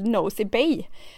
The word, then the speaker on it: Nosy Be: Alltså italienarna åker på charter till Nosy Bay. Alltså det, det, det stavas N-O-S-Y-B-E, Nosy Bay tror man då att det ska uttalas, men Nosy Bay.